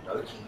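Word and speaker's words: gnocchi: So are you trying to say Noki? Are you trying to pronounce Noki correctly? – Yeah, spelled with a G.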